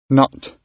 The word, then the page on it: nut: British English